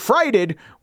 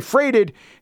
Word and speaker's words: freighted: For instance, a listener wrote in to say that I pronounce freighted frighted when it should be freighted.